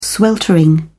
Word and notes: sweltering: British English

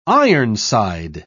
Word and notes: ironside: British English

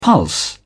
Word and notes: pulse: British English

